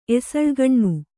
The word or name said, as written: ಎಸಳುಗಣ್ಣು